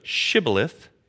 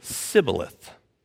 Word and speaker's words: Shibboleth: So instead of Shibboleth, you get Sibboleth.